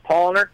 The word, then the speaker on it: Paulaner: Yeah, we're going to start off with the Paulaner, which in English you pronounce Paulaner, but it's P-A-U-L-A-N-E-R. In German, pronounced Paulaner. Okay. The accent on the middle syllable.